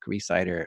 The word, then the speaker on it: Rhysider: And I'm most active on Twitter, which my name there is Jack Reesider on Twitter. – Yep, and that's spelled R-H-Y-S-I-D-E-R.